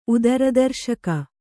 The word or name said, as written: ಉದರದರ್ಶಕ